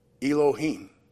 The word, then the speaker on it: Elohim: Number three, Elohim, E-L-O-H, what? Elohim. Well, it's very close to Elohim. Elohim. Yes, it's E-L-O-H-E-E-N in English. That's the way it sounds when you pronounce the Hebrew word.